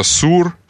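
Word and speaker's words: Sour: Значит, цена нефти North Dakota Sur, Sur, вот так вот, Sur, Sur, Соур пишется, опустилась ниже нуля.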